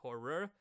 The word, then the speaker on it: horror: Us Kentucky boys really need to pronunciate the end of that word horror.